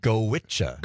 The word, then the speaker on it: Goitse: It's called Gowicha. – Gowicha. – Gowicha. – It's Irish. – Common pronunciation. – Yeah, I know. – Gowicha. How do you get G-O-I-T-S-E, Gowicha? – You'll have to ask the Irish.